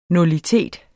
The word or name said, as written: nullitet